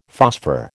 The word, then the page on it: phosphor: American English